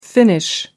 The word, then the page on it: finnish: American English